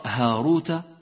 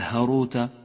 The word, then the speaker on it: هاروت: انتبه إلى الألف الخنجرية التي لونت باللون الكموني في كلمتي هاروت وماروت، هاروت وماروت، هذه الألف إياك أن تهمل لفظها، طريقة رسمها خاصة بالمصحف، اقرأ هاروت ولا تقل هاروت أو ماروت، ولكن أعمل لفظها هاروت وماروت.